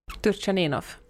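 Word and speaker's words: Turtschaninoff: säg igen. – Turchaninov. – Ja. Men som är lite svårt för mig att uttala. Turchaninov, nej det var det inte. Men, nej, det gick bra.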